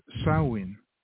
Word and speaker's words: Samhain: So let me just say to anybody who's listening, the topic for our show is Samhain, pronounced Samhain.